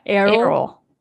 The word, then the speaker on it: Errol: Errol. – So like Carol, but without the C. – Yeah. – Errol.